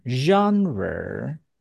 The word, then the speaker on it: genre: 然后英文把这个变成 r 来念，然后 g 呢也不是念 g，而是念 r，导致这个字的发音就是怪怪的。对，啊 e 呢，e 居然是念 e 的音哦，有没有觉得很怪？的确，e 字尾是不是大部分都不发音？啊，这个有发音，而且居然是念 e，所以才念成 genre。